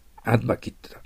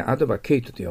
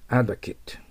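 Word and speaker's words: advocate: はい、次は、ちょっと発音が難しいんですけど、首相は消費税のアドバキットだ。名詞はね、アドバケイトと読まないんですよ。これ、アドバキットと読みます。で、動詞はアドバケイトと言いますけどね。アドバキット。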